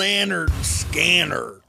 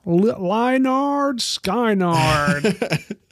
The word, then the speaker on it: Lynyrd Skynyrd: It's pronounced Lynyrd Skynyrd. – Lynyrd Skynyrd.